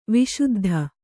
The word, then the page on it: ವಿಸುದ್ಧ: Kannada